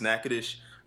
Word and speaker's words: Natchitoches: Speaking of Thanksgiving, I even remember visiting my family in Natchitoches, which is, like, if y'all don't know Natchitoches, Louisiana, it's spelled like Natchitoches, but it's pronounced Natchitoches. At least that's the way people pronounce it.